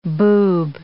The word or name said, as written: boob